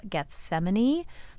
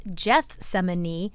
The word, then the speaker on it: Gethsemane: So then she was ruled correct, and all of these people are online saying, wait, but he used the hard G. It is Gethsemane. And yes, it is Gethsemane, but it's not Gethsemane.